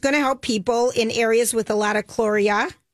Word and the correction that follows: cholera: Am I pronouncing? – Cholera. – Cholera.